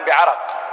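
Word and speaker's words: عَرَق: مسألة: قوله فأتي رسول الله صلى الله عليه وسلم بعرق بفتح الراء، هكذا ضبطها المتقنون أنها بفتح الراء، أما نطقها بالسكون بعرق فهذا جاء، ولكن أهل العلم قالوا هذا ليس ضبطا صحيحا لللفظة، وأن اللفظة ضبطها الصحيح بفتح الراء، والعرق فسره سفيان بن عيينة بإيش؟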